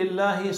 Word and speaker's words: الله: هذه الألف تلفظ ولا تكتب. كيف بتكتب لفظ الجلالة؟ ألف لام لام هاء. تكتب شيء بين اللام واللام ألف؟ ما بتكتب، لكن بتلفظها، بتقول الله.